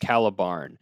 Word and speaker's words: Caliban: Um, and there's a correction email that says a redditor has informed me that there is a slight katakana difference. That means it is indeed pronounced Caliban. Although apparently some translations, like the Thai subtitles, are still using Ca- uh, Caliban. So it's Caliban.